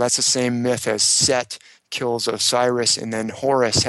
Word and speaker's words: Seth: or sometimes spelled Seth, but usually pronounced Set.